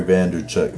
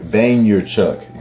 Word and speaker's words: Vaynerchuk: Gary V, Gary Vaynerchuk. Vaynerchuk. Excuse me for my pronunciation. Mispronunciation, rather.